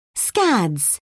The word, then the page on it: scads: British English